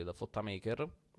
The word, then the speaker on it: The Fotta Maker: Nel 2004 esce questo disco, il suo primo album solista, intitolato The Fotta Maker, come lo pronuncia lui, The Fotta Maker.